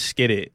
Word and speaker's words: Esskeetit: It's Skitt It. – It's really spelled like that? – Yeah, E-S-S-K-E-E-T-I-T.